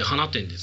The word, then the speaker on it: 放出: 石の巻、柏、阿公、花店、咲いたになりますね。 – ああ、わかんない。 – 花店って読むんですか。 – はい。放出って書いて花店ですね。